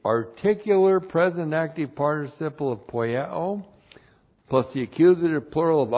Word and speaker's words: poieo: We have the verb poieo. And we've seen this word many times. P-O-I-E, long O.